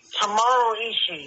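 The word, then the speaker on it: Tomohiro Ishii: How do you pronounce his name here? – Tomaro Ishii. He's from New Japan Pro Wrestling. – There you go.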